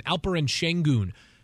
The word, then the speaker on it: Alperen Şengün: But then, later in the first round, 16th overall, took a player in Alperen Şengün, a big man who was the Turkish Basketball League MVP.